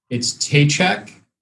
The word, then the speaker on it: Tejcek: No, not that it matters, but it's Tejcek.